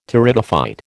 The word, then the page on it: Pteridophyte: American English